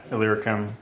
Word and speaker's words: Illyricum: Yeah, Illyricum, Illyria. I only know how to pronounce that because it's in Shakespeare plays. And Krista taught me how to pronounce it.